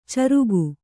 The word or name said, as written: ಚರಿಗೆ